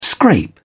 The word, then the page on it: scrape: British English